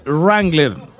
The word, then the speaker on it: Wrangler: La W no se pronuncia. Wrangler.